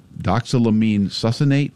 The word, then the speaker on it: doxylamine succinate: It's this doxylamine succinate. Is that how you pronounce it? – Yes, that's the active ingredient.